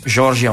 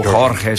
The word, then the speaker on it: Jorge: Così un po' sempre scherzosamente, diciamo, insomma, abbiamo preso un bastimento e siamo arrivati a Salvador de Bahia, dove abbiamo conosciuto Amado, Jorge Amado, o Jorge, se lo pronunciano in spagnolo.